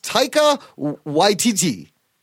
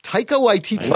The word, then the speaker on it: Taika Waititi: I think it's pronounced Taika Waititi. Taika Waititi. – Wow. – Yeah. Taika Waititi.